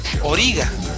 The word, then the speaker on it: Origa: La música de esta serie es interpretada por la cantante rusa Origa. Bueno, los japoneses le dicen Origa, porque así pronuncian ellos el nombre de Olga.